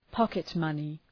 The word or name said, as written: pocket money